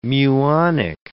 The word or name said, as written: muonic